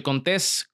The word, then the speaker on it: Contés: Mi nombre es Miguel Contés, con acento en la E, y nos vemos en la próxima.